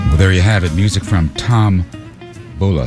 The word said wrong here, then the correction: Vullo: Did I say that right? I think I did. Todd Vullo, V-U-L-L-O.